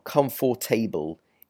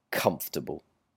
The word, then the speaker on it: comfortable: It's not comfortable, it's simply comfortable. And pay attention to this as well, because with the A-B-L-E ending, lots of people mispronounce this able, like vegetable or whatever. In this case, when you see that A-B-L-E ending, it's able, comfortable, vegetable, and so on.